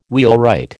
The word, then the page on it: wheelwright: American English